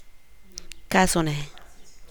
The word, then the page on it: kazuneng: Teotitlán del Valle Zapotec